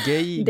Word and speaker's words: Gueye: Är det gay? – Gay säger man här.